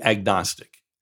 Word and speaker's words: agnostic: Agnostic, the prefix isn't ag. It's not about farming or agriculture. The word breaks up with the prefix a, meaning not, and then the word Gnostic, G-N-O-S-T-I-C, the G is silent, and somehow it's not silent when you throw the A in front of it.